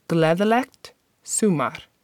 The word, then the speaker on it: Gleðilegt sumar: So the first part of the word is su, and the last part is mar. Sumar. You can see how both those words are spelled in the show notes. But one last time, the word is pronounced Gleðilegt sumar.